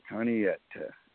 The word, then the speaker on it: Chanayetta: C-H-A-N-A-Y-E-T-T-A. And the C is silent. Kaniyeta. – Okay, Kaniyeta.